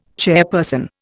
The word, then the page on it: chairperson: Indian